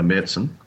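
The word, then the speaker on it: Märzen: Whereas if they just put out a Little Creatures Marzen, or if Sam Fuss is listening, a Merzen, because she'll give me a smack for mispronouncing it, that there wouldn't have been, the perceived disappointment that seems to have followed it.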